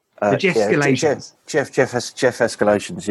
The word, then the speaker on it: Jeff Escalations: Well, talk about the Jeff Galatians, or Jeff, I'm saying it wrong, was it? – Jeff Escalations, yeah.